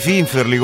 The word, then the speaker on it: finferli: E comunque voglio dirvi questo: in un mare di dei finferli, come si chiamano? – Finferli. – In un mare di finferli. Siate quelli con l'accento sulla seconda sillaba, va bene?